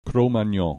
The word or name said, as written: Cro Magnon